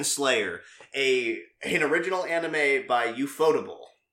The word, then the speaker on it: Ufotable: It's Ufotable is the official pronunciation.